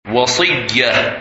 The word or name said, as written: Wasiyah